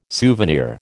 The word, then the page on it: souvenir: American English